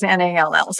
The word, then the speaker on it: Nall: It's spelled Nall Edge Co. – Well, my last name is Nall. So it's a play on words.